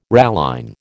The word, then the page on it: ralline: American English